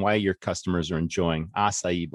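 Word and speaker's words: açaí: I think she pronounces it acai bowl.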